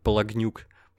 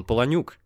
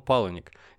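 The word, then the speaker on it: Palahniuk: И поэтому он даже пишется по-английски как Палагнюк. Он Паланюк в оригинале. Но он-то американец уже, и поэтому он Паланик.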